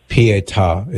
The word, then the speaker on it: Pietà: P-I-E-T-A. Pietà is how it's pronounced.